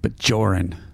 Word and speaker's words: Bajoran: Bajoran. Hard J.